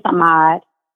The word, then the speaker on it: Ahmad: And just out of curiosity, did I pronounce his name correctly? – It's Ahmad. – Okay. – You all did. Right. You said it perfect.